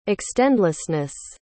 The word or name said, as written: extendlessness